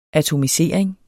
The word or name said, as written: atomisering